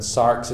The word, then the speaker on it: sarx: How do you spell it? – S-A-R-X. – Sarx. – In English. As opposed to soma, S-O-M-A.